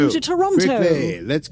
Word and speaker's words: Toronto: So they just need to take that back to Toronto, which, by the way, I've been pronouncing like an American this whole time. Apparently, you're supposed to call it Toronto. I know that, and I'm sorry that I am mispronouncing the city name.